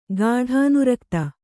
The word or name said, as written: ಗಾಢಾನುರಕ್ತ